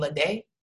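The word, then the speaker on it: Ledé: I believe she pronounced her last name Lede.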